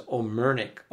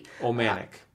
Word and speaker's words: Omanique: I remember myself seeing that name spelt and pronounced Omanic, sometimes with O-M-A-N-I-Q-U-E, sometimes O-M-A-N-I-Q-U-E. And more often than not, when I was growing up here, it was Omernick, O-M-E-R-N-I-U-K. So just say that again. In the family that you grew up in, when the family name was mentioned, how was it pronounced and how was it spelt? – Well, I was raised as O-M-A-N-I-Q-U-E.